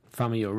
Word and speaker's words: Famuyiwa: Famuyiwa? – Yes. I'm going to just. – Famuyiwa. Rick Famuyiwa. – Good one. – There you go. You say it correctly.